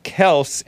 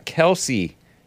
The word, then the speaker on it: Kelce: It's actually pronounced Kels and not Kelsey, says Big Bump about the guy.